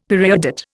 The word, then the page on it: periodate: Indian